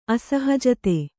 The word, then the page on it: ಅಸಹಜತೆ: kannada